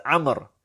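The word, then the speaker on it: Amr (عمرو): Well, that waw shows us, denotes to us, tells us that this is not Umar, but this is Amr. So we know how to pronounce it. This waw is not pronounced, but by the presence of that waw, we know whether it's an Amr or whether it is an Umar, inshallah.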